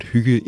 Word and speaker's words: hygge: One theory involves a word that is spelled H-Y-G-G-E. – It's pronounced hygge.